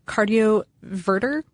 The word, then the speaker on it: cardioverter: There are also related, implantable cardioverter. Did I say that right? – Yep.